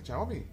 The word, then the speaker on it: Xiaomi: Los voy a regalar gracias a Shao ¿Cómo es? Xiaomi. – Xiaomi. Xiaomi. – Xiaomi. Xiaomi. – Xiaomi tienen, ¿Cómo se dice? Ah, Xiaomi. Xiaomi. Xiaomi. Xiaomi. Así como Xiaomi de money.